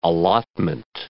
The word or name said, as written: allotment